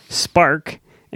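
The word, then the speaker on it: SPARC: She's the executive director of Spark.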